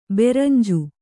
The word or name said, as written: ಬೆರಂಜು